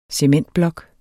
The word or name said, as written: cementblok